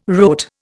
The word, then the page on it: rote: Indian